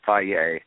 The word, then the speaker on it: FAGE: F-A-G-E. Get the plain, just like Kim said. F-A-G-E, they pronounce it Faye.